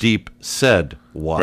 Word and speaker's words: Deep Said What: Deep said what. – Right, because that's how it's spelled, S-A-I-D.